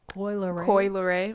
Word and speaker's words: Coi Leray: How do you say it? Benzino seed? – Coi Leray.